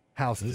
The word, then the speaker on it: Houston: Houston.